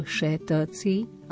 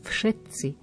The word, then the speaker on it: všetci: Problematické slovo je všetci a tak často ho máme v lekciách používané. F je na začiatku, čiže nie všetci, ale všetci.